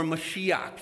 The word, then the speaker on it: Mashiach: And they begin to use the word Messiah or Mashiach. That's how you say it in Hebrew.